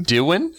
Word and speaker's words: Dewin: Dewin. It's like someone said Dylan with a speech impediment.